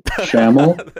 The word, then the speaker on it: Schamell: I don't even know how to pronounce my own name. Schamell is normally what it is.